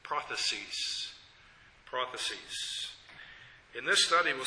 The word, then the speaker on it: prothesis: And that first is prophecies, with the accent on the first syllable.